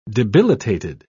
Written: debilitated

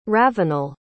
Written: ravenel

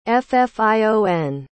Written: ffion

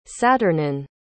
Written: saturnin

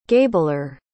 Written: gabler

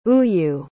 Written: ooyu

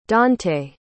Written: donte

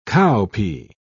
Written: cowpea

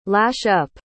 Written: lash-up

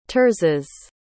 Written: terses

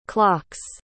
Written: clocks

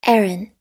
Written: Aran

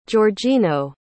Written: georgino

